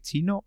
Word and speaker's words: Qi: Yo creo que es Chi pero porque Q es una letra, eh, no sé si es porque es chino o porque es una letra griega o algo así, ¿eh? no me digas el por qué, pero se escribe q QI, pero es chi.